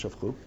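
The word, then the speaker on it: shafchu (שפכו): it's written shafcha, and it's pronounced shafchu, Et adam, v'chit ale adatcha, She'sanhedrin hargin, Ela lo patrininu b'lo mazon u'b'lo l'viya.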